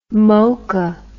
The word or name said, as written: mokha